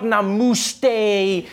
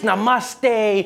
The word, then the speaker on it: namaste: People were coming on like, dude, it's not namuste, it's namaste. Who messes up namaste says namuste?